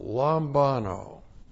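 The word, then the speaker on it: lambano (λαμβάνω): Looks like that in the Greek, L-A-M-B-A-N long O, lambano.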